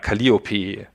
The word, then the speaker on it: Calliope: Also im Deutschen betont man die Musen so hinten eher Calliope. – Ja, ich weiß nicht, wie es im Englischen ausgesprochen wird. – Naja. – Calliope hätte ich jetzt gesagt, aber vielleicht ist irgendein englischer Muttersprachler unter unseren Zuhörern und kann das aufklären.